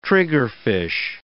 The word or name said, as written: triggerfish